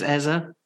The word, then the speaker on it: Eze: So if we are going to go with his surname, then I think it's Eze.